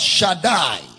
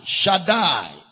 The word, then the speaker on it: Shaddai: The word Shaddai. Shaddai.